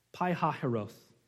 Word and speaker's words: Pi-Hahiroth: That's how you say it in Hebrew. I don't know how else you want to say it. Pi-Hahiroth.